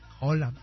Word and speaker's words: Haaland: Ya, pero por dentro me dicen, Haaland.